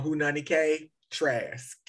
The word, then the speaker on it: Haunani-Kay Trask: Oh, Hunani K. Trask.